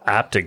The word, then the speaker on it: Apted: I'm glad you pronounced it Michael Apted because I did not know his name. Right. And well, that's how I said it.